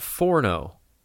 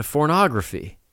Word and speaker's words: pornography: He misheard and said, like, yeah, you don't want all that forno. And I was like, huh? He said, yeah, pornography. And I didn't have the heart to tell him, Pappy, you're saying it wrong. There's a hard P.